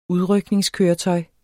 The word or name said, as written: udrykning